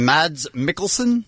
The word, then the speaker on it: Mads Mikkelsen: We have, tell me if I'm saying this right, Bean Mads Mikkelsen. – That's correct.